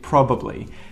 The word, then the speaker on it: probably: Same thing happens here in number five, probably. This one, again, too long, we get rid of one of those syllables and we say probably.